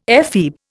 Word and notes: ephebe: Indian

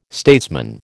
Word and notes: statesman: American English

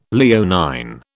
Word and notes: Leonine: British English

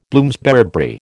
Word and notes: Bloomsbury: American English

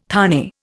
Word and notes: thane: Indian

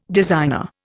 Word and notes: designer: Indian

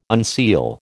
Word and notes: unseal: American English

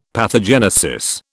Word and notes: pathogenesis: British English

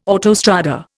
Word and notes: autostrada: Indian

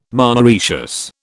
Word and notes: monoecious: British English

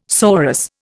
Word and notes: sorus: Indian

